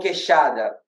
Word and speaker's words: queixada: So, we call queixada.